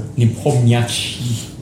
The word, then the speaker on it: Nepomniachtchi: It's Jan Nepomniachtchi. – Oh, for God's sake, I can't even pronounce that properly. – Nepomniachtchi.